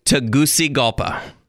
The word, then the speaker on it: Tegucigalpa: I was pronouncing it Tegucigalpa for a long time, but that's wrong. – What is it again? – Tegucigalpa.